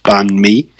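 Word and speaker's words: bánh mì: Their two classic dishes, I guess, that Vietnam's known for is one called banh mi, which is sort of pronounced like ban, B-A-N, space, M-E, so banh mi.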